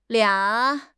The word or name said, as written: lia3